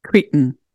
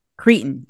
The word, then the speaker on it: Cretan: Now, okay, how do you say it? Is it Cretan or Cretan? – Cretan. – Cretan. Okay. Cretan cheeses.